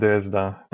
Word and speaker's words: Dehesdin: All right, say your name properly. This is a dumpster fire. Go ahead. – Sebastian Dehisten. – Oh, sounds so much better.